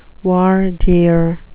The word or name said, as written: voir dire